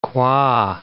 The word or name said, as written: Kwa